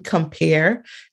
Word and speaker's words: Compear: You know, when I first came up with the synonym Compare, as I spell it, C-O-M-P-E-A-R, like the fruit,